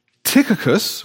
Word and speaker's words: Tychicus: Paul, not being able to visit them personally because he's behind bars, sends two of his disciples, Tychicus,